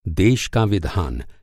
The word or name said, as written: देश